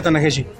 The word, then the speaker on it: Ta-Nehisi: Pero se pronuncia Tanaheshi.